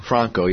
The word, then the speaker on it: Franco: You know, what's it, Franco, how do you say his name? – Franco, yeah.